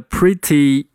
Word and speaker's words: pretty: 这个里面的 pretty 把 t 变成一个 d 的发音，我们把这个把我们把这个过程叫做浊化。 pretty 变成 pretty pretty pretty good 里面的 d 省略掉，变成 good good。